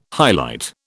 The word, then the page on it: highlight: British English